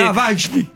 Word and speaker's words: Navalny: Navalny. – Perché io sono russo, dico la pronuncia esatta,